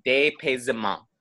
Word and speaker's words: dépaysement: So this first word I can pronounce, and it's pronounced dépaysement, which is the French word, which is closely related to the word disorientation.